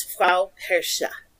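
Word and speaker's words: Frau Perchta: Yeah, it's spelled P-E-R-C-H-T-A. But the C-H-T-A is soft, so it's Frau Persche.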